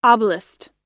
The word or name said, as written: oblast